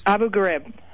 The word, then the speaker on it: Abu Ghraib: but I just want to hear you say it correctly once. – Abu Ghraib.